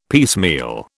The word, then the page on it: piecemeal: British English